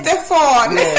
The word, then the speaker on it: Stefon: No, I know with me, I'm pronouncing it as if it's an A, because I'm from Grenada. We say everything Stefon, you know. It's Stefon. – It's S-T-E-F-O-N and yes. J-O-H-N. – Mm-hmm. – And on Instagram, same thing, S-T-E-F-O-N underscore God, G-O-D underscore Son, S-O-N underscore John, J-O-H-N.